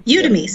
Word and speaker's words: Udemy: Udemy.